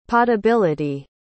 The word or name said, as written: potability